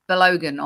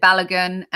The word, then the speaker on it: Balogun: I would throw, not Eddie, I'd throw Balogun on there. Now, I know some people pronounce it Balogun and some Balogun, right? – Balogunner. – So we'll keep it at Balogun for today.